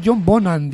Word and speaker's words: Bonham: Bueno, luego otra gente como Charlie Watts, Keith Moon, Ginger Baker, Mitch Mitchell, John Wonham. – Bueno, dicen John Bonham, dicen. – Sí, bueno, yo Bonham. – Dije, no, es que lo pronuncio yo mal.